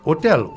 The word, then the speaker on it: hôtel: Por ejemplo, si yo dois dire je vais à l'hôtel, l'hôtel, H-O-T-E-L, hôtel, un hôtel, pero como la H no se pronuncia, la H es mudo, yo no puedo decir je vais à le hôtel.